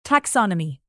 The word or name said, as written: taxonomy